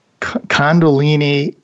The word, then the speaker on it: Kundalini: Kundalini energy. Did I pronounce that right? – Yeah, it's pretty good. Yeah, I think you pronounced it right, just with a slight American accent, which I'll let you off for that.